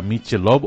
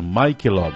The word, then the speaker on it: Michelob: La gente suele decir el nombre de esa cerveza, Michelob o Michelob, ¿verdad? Pero lo correcto es Michelob. Ese es el nombre correcto a pronunciar.